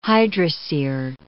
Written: hydrosere

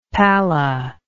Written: palla

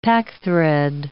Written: packthread

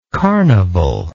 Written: carnivallike

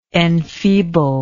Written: enfeebler

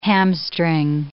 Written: hamstring

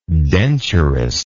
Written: denturistism

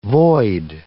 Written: void

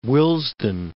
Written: Willesden

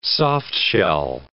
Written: soft-shell

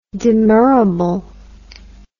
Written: demurrable

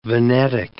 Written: Venetic

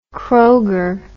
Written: Kroger

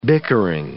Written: bickering

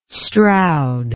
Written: stroud

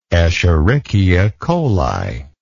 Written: escherichia coli

